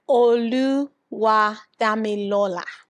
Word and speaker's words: Oluwadamilola: So my full name is Oluwadamilola.